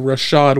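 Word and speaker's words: Rachaad: So if I combine the flex with the two backs, and then on Gurdy's side, Stevenson, Foreman, and Rashad White, not Rashid White, I've corrected my pronunciation.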